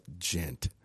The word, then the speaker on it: djent: It has a J in it, but the J is silent, and it ends with a T. Music, they call it djent.